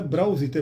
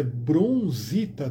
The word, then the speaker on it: bronzita: É, não é brausita, é bronzita, de bronze.